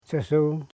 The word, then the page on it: chesu: che.su